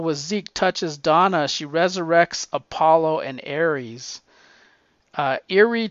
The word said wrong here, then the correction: Eirene: takes off. I don't know if that's the right way to pronounce it. It's Irene, Eirene takes off.